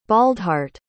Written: baldhart

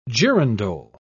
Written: girandole

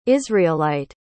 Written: israelite

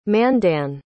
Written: Mandan

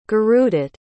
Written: gurudutt

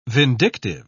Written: vindictive

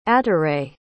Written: ataret

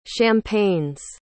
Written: champagnes

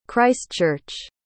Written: christchurch

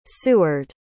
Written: seward

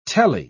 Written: telly

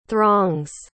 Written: throng's

